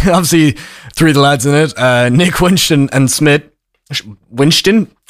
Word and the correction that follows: Schmidt: I messed up my funny pronunciation of an S there. Nick Winston and Schmidt, we can very easily sort of attach those sort of archetype of characters to our little trio.